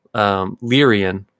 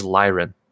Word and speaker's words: Lyran: Yeah, and then in the early days, I always said Lyrian. And now it's like, I look and I'm like, oh, I was wrong. It is Lyran.